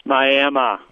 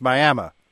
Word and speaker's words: Miami: It's Miami. – Okay. – It's really spelled Miami. – Oh, but it's pronounced Miami. – Right. – There in Oklahoma.